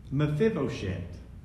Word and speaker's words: Mephibosheth: The Hebrew name is actually pronounced Mephibosheth, meaning from the mouth of God Bashtu.